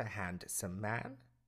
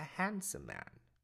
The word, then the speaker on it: handsome: Silent D. He is a handsome man. Not a handsome man. A handsome man.